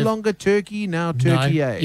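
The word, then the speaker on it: Türkiye: But the commentators, particularly the Australian commentators, did not call their opponents Turkey because they are from Turkey. No, because it's spelt in their own language. – Turkey A. – And that's what they call them, yeah. Turkey A.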